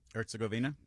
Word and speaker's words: Herzegovina: Herzegovina.